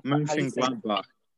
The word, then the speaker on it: Mönchengladbach: Oh, Mönchengladbach.